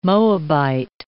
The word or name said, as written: Moabite